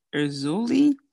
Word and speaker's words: Erzulie: I believe it's pronounced Erzuli, E-R-Z-U-L-I-E.